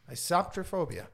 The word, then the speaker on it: isoptrophobia: And let me try to pronounce this. It's a isoptrophobia. – Isoptrophobia. – Yes. Isoptrophobia.